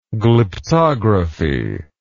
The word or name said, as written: glyptographical